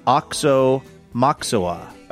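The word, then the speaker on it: Aoxomoxoa: It's pronounced Oksa-moksa.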